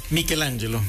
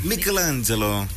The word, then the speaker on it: Michelangelo: Michelangelo. – Michelangelo.